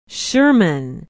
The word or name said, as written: sherman